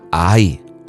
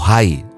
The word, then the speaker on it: Hai: Iban a tomarse a Ai o Hai, le llaman porque la pronunciación de la H es más con la J según el hebreo.